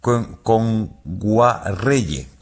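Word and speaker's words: Kngwarreye: Emily Kame Enguayerre, lo he dicho mal. – Emily Kame. – Con Guarreye.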